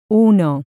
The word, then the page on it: uno: Spanish